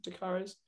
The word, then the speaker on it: de Caires: And I think they did have Robbie White down at number four, but Atherton's son, de Caires, is that the right pronunciation? de Caires? – Yeah.